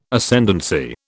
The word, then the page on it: Ascendancy: British English